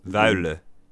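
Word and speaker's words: Wuille: Oh, I know Peter Wille, Wolle, whatever you pronounce. – Wille. – Yeah, that guy. – Wille. Everybody says it wrong because well, he's Belgian, so. But it's Peter Wille.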